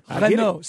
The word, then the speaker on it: Renault: I just want to say the T, Carl. – I understand. – Renault. Say Renault.